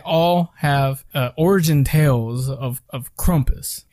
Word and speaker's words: Krampus: So I think I vaguely mentioned this, but there's a Christmas character called Krampus, or Krumpus, however you want to pronounce it. I think Americans say Krampus, and I believe it's supposed to be pronounced Krumpus.